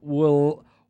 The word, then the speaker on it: Willamette: It's Willamette. It's Willamette.